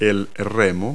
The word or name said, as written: oars